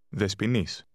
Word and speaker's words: Δεσποινής: Δεσποινής.